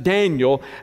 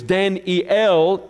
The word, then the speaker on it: Daniel: Because you see, the name Daniel, we pronounce it Daniel, actually it was Dan-E-L, E-L meaning God, means God is my judge. But he was named Belteshazzar because Bel, B-E-L, was one of the four Babylonian gods.